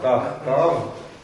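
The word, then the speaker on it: тахтав (תחתיו): Читаем «камац» и «вав». Йод не читаем. – Ясно. – То есть произносим «элав», «алав», «тахтав» и «ахарав». Йод остается только на письме.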